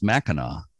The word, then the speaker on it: Mackinac: Mackinac City is spelled with a W at the end, but the name of the point and the Straits of Mackinac are spelled with a C at the end, MACKINAC. But either way, it's pronounced Mackinac. And I used to think it was two different pronunciations, but it's not. It's Mackinac, no matter how it's spelled.